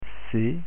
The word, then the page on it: c: French